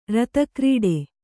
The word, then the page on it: ರತಕ್ರೀಡೆ: kannada